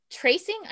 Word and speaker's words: TRCNG: Tracing, I think it's how you pronounce their name. Wolf Baby. – Wolf Baby, that was on my list. – TRCNG, if you're wondering.